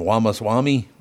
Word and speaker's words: Ramaswamy: Ramaswamy was his name? – Nailed it.